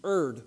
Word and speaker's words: erred: But I'm just saying, if you look it up in the dictionary and in the NBC Handbook of Pronunciation, it's err. You erred from the faith.